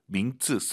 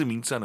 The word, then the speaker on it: 名字: 선생님, 정말 많은 초급자분들께서 名字 민츠, 쌍지읒으로 많이 발음을 하세요. 알아들으니까 아무도 그걸 고쳐주지 않으니까 우리가 계속 민츠, 민츠 하는 거예요. 제대로 하셔야죠. 민츠, 제 학생, 제 청취자분들이라면 민츠 하시기 바랍니다.